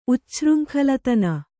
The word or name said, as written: ಉಚ್ಛೃಂಖಲತನ